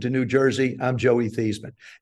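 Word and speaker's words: Theismann: She says, actually, the correct pronunciation is Thiesman, and they're closer than what we have now.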